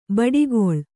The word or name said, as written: ಬಿಡುಕುಳ